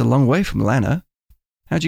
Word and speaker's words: Lanner: During this moment, I was in a place called Lanna. – Lanna, how do you spell that? – LANNER.